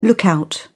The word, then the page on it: lookout: British English